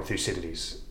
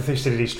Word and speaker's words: Thucydides: We want to talk about a couple of concepts that come from Thucydides.